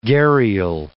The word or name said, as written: gharial